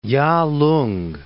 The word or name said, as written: Yalung